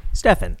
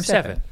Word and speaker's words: Stephan: I said your name right? Right? – Stephan. – Yes. Graham Stephan.